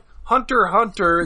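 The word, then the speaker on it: Hunter × Hunter: I think it's called Hunter Hunter. The X isn't pronounced at all. – Oh, the X is silent.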